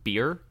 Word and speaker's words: bier: It's pronounced beer? Like the drink? – So. Yeah. Beer. B-I-E-R. Pretty sure.